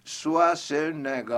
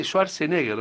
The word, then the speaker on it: Schwarzenegger: Schwarzenegger, ¿no? – Schwarzenegger. – Sería Schwarzenegger. – No, ga. Termina en ga.